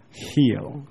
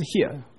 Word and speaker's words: heal: 中间那里说 he healed the sick， heal heal h e a l，也很多台湾人都读到 here，那不是 here，有 L 的音，那读作哦。